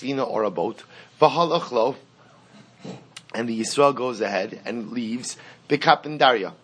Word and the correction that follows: Bekapandria: Bekapandria is the right pronunciation.